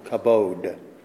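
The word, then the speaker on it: kabod (כָּבוֹד): We would spell it in the English, K-A-W-K-A-B-O-D-E, long O, kabod.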